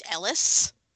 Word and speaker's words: Eris: which is spelled Eris with a silent H, but it's pronounced Ellis.